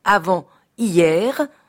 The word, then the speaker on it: avant-hier: Et j'appelle avant hier, que je prononce avant-hier, le jour qui a précédé hier.